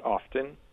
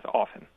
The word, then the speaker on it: often: One example is the word often, mm-hmm. – Which you might hear pronounced often with that audible T. – Yeah. – So that's much more common the younger that you are. Whereas if you're older, it's more likely to be a silent T, just as often.